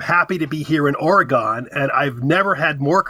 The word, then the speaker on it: Oregon: Well, you're from California, so you would pronounce it correctly. But here on the East Coast and in the Central States, we all pronounce Oregon, Oregon. And that drives Oregonians crazy.